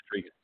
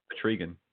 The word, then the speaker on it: Etrigan: Etrigan. – Say it again. – Etrigan.